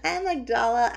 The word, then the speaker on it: amygdala: Amygdala? – Yeah, amygdala. Amygdala. It's A-M-Y-G-D-A-L-A, and I can no longer remember how to pronounce it.